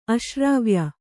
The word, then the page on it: ಅಶ್ರಾವ್ಯ: aśrāvya